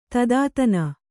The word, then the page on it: ದೂತತನ: dūtatana